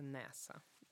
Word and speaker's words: NASA: Одна из немногих аббревиатур, которая произносится как слово, это NASA. – А я не знаю, как аббревиатура студента. Студентка. S-T-U-D-E-N-T.